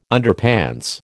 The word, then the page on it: Underpants: American English